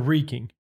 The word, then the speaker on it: wreaking: Had I thought about wrecking havoc on an opposition. – Wrecking or wreaking? – Wreaking. – I was going to say. Is it wrecking? – You would say wreaking. – How do you spell it? – W-R-E-A-K.